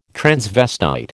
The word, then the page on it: transvestite: American English